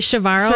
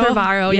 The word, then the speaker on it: Chavarro: how do you say his last name? – Oh, that's a tough one. – It is one. – Jorge Chavarro. – Chavarro, yeah.